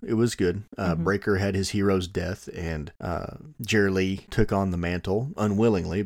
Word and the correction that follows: Geraldine: Geraldine. You said Jerry Lee? – I'm not going to say it right ever.